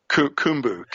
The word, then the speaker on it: Khumbu: Is it pronounced Kumba or Kumbra? – Kumbu, K-H-U-M-U-M-B-U.